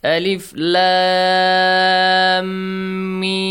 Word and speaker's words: Alif Lam Mim: So how do you say this? Alif lam mim. Alright, count. Six fatha. – Yes. – Six kasra. Sound, okay, count it. Alif lam mim. Six counts, okay?